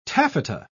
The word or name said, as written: taffeta